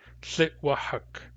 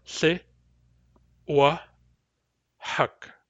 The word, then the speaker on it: Thitwahak: The syllables in Thitwahak are Thi, Wa, Huck.